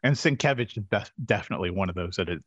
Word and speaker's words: Sienkiewicz: It's pronounced Sienkiewicz. – Sienkiewicz. – It's Sienkiewicz.